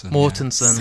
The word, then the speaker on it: Mortensen: Viggo Mortensen, is that how you say it? – Mortensen. – Yeah. So close.